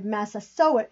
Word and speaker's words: Massasoit: She told me that based on the research they've uncovered, Massasoit is the correct pronunciation.